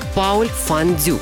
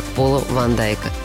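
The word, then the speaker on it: Paul van Dyk: По правилам чтения немецкого его псевдоним произносится как Пауль Фан Дюк – но мировое сообщество знает его как Пола Ван Дайка.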